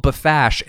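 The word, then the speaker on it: Bpfassh: How do you pronounce that? – That's how I would have pronounced it. Bafash.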